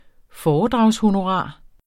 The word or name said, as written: foredragshonorar